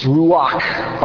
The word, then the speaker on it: ruach: It's ruach, all right, R-U-A-H with a dot underneath the H in order to say this needs to be pronounced with a real guttural kind of close.